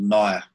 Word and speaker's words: Nyah: I was born and bred in a little place called Nyah, which is spelt N-Y-A-H, and it's an indigenous name for bend in the river.